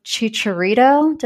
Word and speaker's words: Chicharito: But there's going to be no Chicharito.